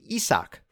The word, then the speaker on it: Isaac: I said his name is Isaac Paredes. It is actually Isak Paredes. That is how he pronounces it. This week, MLB released its new guide to player name preferences and pronunciations, and I learned that Paredes pronounces his first name Isak. It is spelled I S A A C, which is what tripped me up,